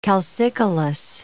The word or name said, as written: calcicolous